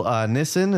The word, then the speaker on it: Nissin: Nissin, is that how you pronounce the company's name? Mm-hmm.